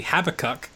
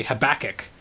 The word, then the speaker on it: Habakkuk: we say in Australian English, we say Habakkuk. And Americans, I don't know how English people say it, but Americans say Habakkuk.